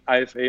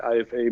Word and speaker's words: IFA: I'm running the IFA in Berlin, or IFA as you say. It's a German pronunciation.